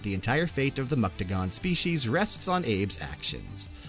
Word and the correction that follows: Mudokon: Mudokon, not Muktagon. I said that wrong there.